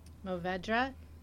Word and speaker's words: Mourvèdre: Mourvèdre.